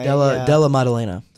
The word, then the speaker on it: Della Maddalena: Don't understand how, but it's our boy Randy Brown taking on Jack Dellen Maddalena? I don't know how you pronounce that. – Sounds right. – Della Maddalena.